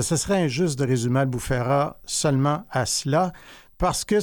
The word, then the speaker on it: Albufeira: La ville, je ne sais pas comment on la prononce, Albufeira? – Albufeira.